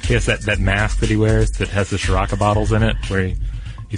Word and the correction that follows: Sriracha: Yeah, he's created the Sriracha biosphere actually for himself. – Oh, I said it wrong. I always say it wrong. I always say Shiraka, but I guess there's not even an H in there.